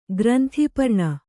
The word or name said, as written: ಗ್ರಂಥಪರ್ಣಿ